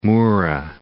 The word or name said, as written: Mura